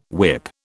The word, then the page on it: whip: British English